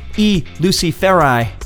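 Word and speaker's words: I Luciferi: Both from the 2002 record, which is pronounced, according to Glen Danzig, E. Luciferi.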